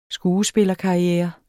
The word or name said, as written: skuespillerkarriere